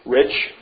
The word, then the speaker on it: Rich: In the July 1974 issue of Truth Magazine, the periodical of the Grace Gospel Fellowship, Mr. Ray Reich, or Wright, Rich, how do you say it? – Rich.